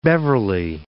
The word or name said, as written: Beverley